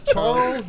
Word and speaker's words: Charlize: Charlize Theron.